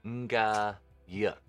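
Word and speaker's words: Ngayuk: Nga-yuk. – Nga sounds like the ing.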